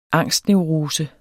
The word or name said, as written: angstneurose